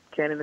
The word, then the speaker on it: Cannon: But if you want to get in touch with me, my name on Facebook, Twitter, and Instagram, Autumn Cannon. Cannon is spelled like C-A-N-N-O-N.